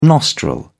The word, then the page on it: nostril: British English